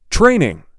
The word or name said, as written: Training